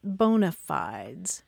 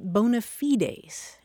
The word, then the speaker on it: bona fides: It was borrowed intact from Latin, and it's spelled the same way, B-O-N-A, and then a separate word, F-I-D-E-S. And in Latin, you pronounce it bona fides, and that literally means good faith.